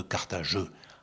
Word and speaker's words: Carthage: Suivi de six autres syllabes, si l'on prononce le E muet, faubourg de Carthageux. Alexandrin, c'était à Mégara, faubourg de Carthageux. Et on doit le dire ainsi, car Carthage est suivi d'une consonne dans les jardins d'Amilcar.